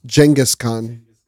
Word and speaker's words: Genghis Khan: You know, funny enough, people say his name incorrectly. It's Genghis Khan.